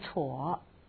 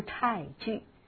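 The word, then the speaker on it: 瘥剧: 欲知菜具，这个菜呢有两个读音，一个读成错，读成错是生病，读成菜呢就是病好。此地我们读菜具啊，好坏就是知道病情的好坏，就必须要去尝尝粪便。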